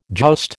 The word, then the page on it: joust: American English